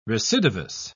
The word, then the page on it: recidivous: British English